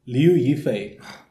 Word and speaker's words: Liu Yifei: Liu Yifei.